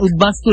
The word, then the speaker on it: উদ্বাস্তু: আপনি যখন উদ্বাস্তু লিখছেন তখন কিন্তু ব উচ্চারিত হচ্ছে